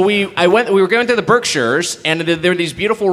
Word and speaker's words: Berkshires: Berkshires.